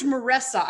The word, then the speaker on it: Meressa: George Meressa.